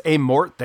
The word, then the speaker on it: Amorth: Yeah, I think it's Amorth. The H is silent.